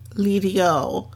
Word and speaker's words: L'Idiot: And he ends up choosing this restaurant that's called Lidio, but it's spelled L'Idiot.